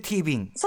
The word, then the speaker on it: テレビイング: そうか、ティビンってのは、はい、テレビイングと書いてティビン。